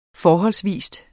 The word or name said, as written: FORHOLDSVIS